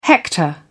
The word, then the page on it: hector: British English